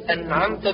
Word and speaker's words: أنعمت: فأما اللحن الجلي فهو الذي يدركه العالم وغيره، مثل النطق بألعمت باللام بدل أنعمت بالنون، أو النطق بأنعمت بضم التاء أو أنعمت بكسر التاء بدل فتحها أنعمت.